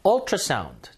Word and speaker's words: ultrasound: Ultrasound. 자, 요 발음이 어에 가까워요. 우, 유처럼 돼 있어가지고 읽을 때 유를 읽을 것 같은데 어.